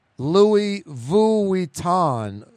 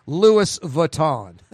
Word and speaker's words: Vuitton: Louis Vuitton. Vuitton. Vuitton. Vuitton. Louis Vuitton. Yeah, I say like two syllables. He's saying it's three.